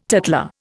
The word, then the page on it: tattler: Indian